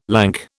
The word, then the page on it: lank: British English